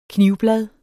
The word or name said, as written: knivblad